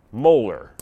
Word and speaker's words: molar: And when you see that unit, it's pronounced molar, just like molar mass.